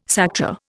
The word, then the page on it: Sakta: Indian